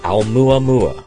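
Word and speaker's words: Aumuamua (ʻOumuamua): Aumuamua.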